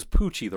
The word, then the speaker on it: Pucci: And it's spelled P-U-C-C-I, like it's an Italian name. I thought it was, because we did the audiobook, I thought it was Poochie, the rockin' dog.